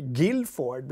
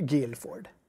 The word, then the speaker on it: Guildford: Gil, jag tror inte de säger, jag tror inte de uttalar det. – Guilford. Jag tror de säger Guilford.